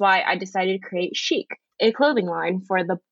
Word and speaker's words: Sheek: So I am actually in the process of creating an eco-friendly women's apparel line called Chic. It's spelled S-H-E-E-K.